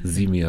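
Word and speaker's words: Symi: Genau, das spricht man das, habe ich das jetzt Simi. Richtig, oder heißt das Simi? – Simi, habe ich immer gesagt.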